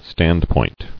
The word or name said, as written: Standpoint